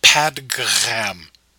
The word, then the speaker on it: Padgham: It's spelled Padgham. I'm going to call him Padgham.